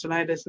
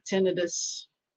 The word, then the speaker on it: tinnitus: Some people that didn't turn off their devices, I heard they got like headaches and tinnitus, which by the way is pronounced tinnitus, not tinnitus.